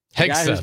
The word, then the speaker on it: Hegseth: Hegseth. – Hegseth, yes.